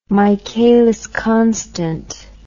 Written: Michaelis constant

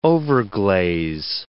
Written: overglaze

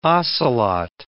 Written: ocelot

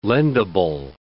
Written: lendable

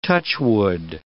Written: touchwood

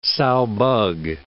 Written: sow bug

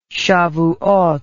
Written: shavuoth

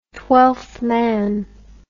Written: Twelfth Day

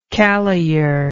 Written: caloyer

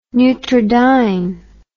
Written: neutrodyne

